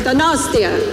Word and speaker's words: Donostia: De bon de nòstia. I'm trying. It's gonna go gosh. Okay. I'm all right. It's gonna go gosh. How do I say it? Donostia.